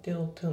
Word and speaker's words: wanted to: で、あと発音なんだけど、この wanted to のところが wanted to っていう感じで発音されてないですね。その wanted の t がね、抜けつつ、後ろの d が、まあ、後ろの to と被ってるっていう感じで、それも抜ける感じで want to。